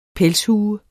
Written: pelshue

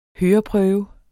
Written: høreprøve

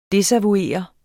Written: desavouere

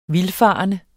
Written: vildfarende

